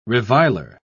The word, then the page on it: reviler: British English